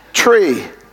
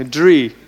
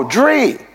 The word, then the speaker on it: drie: How do you say 3? – 3. – That's very close to tray. – Yeah. – Tree. – Nee, drie. – Oh, drie. Okay.